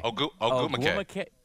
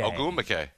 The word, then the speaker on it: Ogwumike: Ogwumike. – I had a Chinne Ogwumike. – Ogwumike.